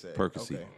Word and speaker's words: Perkasie: I think they can pronounce it Perkasie. – Perkasie. Okay. Perkasie, Pennsylvania.